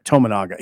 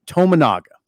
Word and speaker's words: Tominaga: Tominaga, he's not going anywhere. Did I I'm, say it wrong again? Yeah, you did. That's okay. Tominaga.